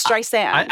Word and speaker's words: Streisand: Don't you say Streisand? – I do. – Well, but it's Streisand.